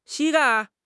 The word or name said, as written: 膝盖